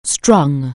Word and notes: string: anglais

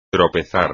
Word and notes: tropezar: espagnol

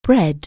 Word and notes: breed: anglais

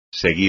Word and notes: seguir: espagnol

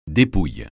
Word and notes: dépouille: francais

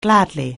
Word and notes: gladly: anglais

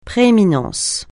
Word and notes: prééminence: francais

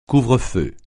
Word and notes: couvre-feu: French

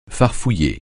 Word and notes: farfouiller: francais

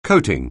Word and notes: coating: anglais

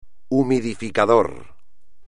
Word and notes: humidificador: espagnol

